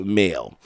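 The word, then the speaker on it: Mhaol: So that's going to conclude this program, but I'm going to leave you with the band Male, and they spell it M and then in parentheses H and then AOL. And I'm glad this is another thing. I'm glad the band put how to pronounce the name, because this would have been another botched up name by myself, but they told me how to pronounce it.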